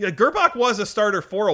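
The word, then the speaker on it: Grbac: It's pronounced Grabak.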